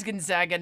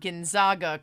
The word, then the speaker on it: Gonzaga: I mean, it is Gonzaga, not Gonzaga, because it's the Zags, not the Zogs.